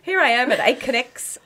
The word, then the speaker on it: Aconex: Chief People Officer of Aconex. Have I pronounced that properly? Because it goes a bit weird in my mouth. – It is. It's actually hard to say. Aconex. – Aconex.